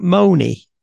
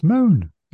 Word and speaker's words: Mone: Are you sure it's not Moni? It's Moan. M O N E. Moan.